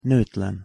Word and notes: notlen: Hungarian

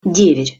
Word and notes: dever: Russian

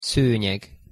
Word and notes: szonyeg: Hungarian

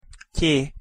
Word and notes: ty: Hungarian

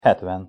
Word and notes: hetven: Hungarian

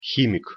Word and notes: himik: Russian

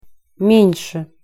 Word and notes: men-se: Russian

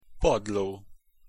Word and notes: padlo: Hungarian

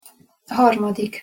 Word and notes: harmadik: Hungarian

